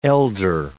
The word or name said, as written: elder